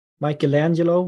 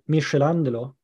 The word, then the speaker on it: Michelangelo: I mean, Michelangelo, we say, or I said when I was a kid, Michelangelo. So Michelangelo. – With a soft C sound instead. Yeah, it's Italian name, so, you know.